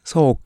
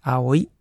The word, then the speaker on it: 葵: そうくん。これは青井優さんの青ですね。で、青いって読むのと、そうっていう風に読むのと。